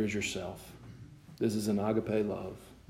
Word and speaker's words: agape: But the proper pronunciation, if you want to have it right, is agape.